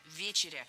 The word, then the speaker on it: вечеря: Гость прав. Действительно, вечеря. Ударение в этом слове ставим на первый слог. И в качестве шпаргалки держим в голове слово «вечер». Вечер – вечеря.